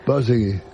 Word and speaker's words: Borzage: Borzegui.